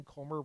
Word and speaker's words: Comer: As part of this investigation, Comer, rhymes with Gomer, said, we would love to talk to people in the Biden family, specifically Hunter and Joe Biden.